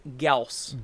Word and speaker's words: Gauss: And everyone I know calls it Gauss, period. It's a Gauss blaster. It's a Gauss flare. – Uh-huh. – It's Necron technology. – Huh. – It is actually pronounced Gauss. – Gauss? – Like a mouse.